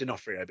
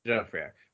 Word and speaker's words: D'Onofrio: I can never say his name. Vincent D'Onofrio. Onofrio? – I said D'Onofrio, but yeah. – D'Onofrio. Right.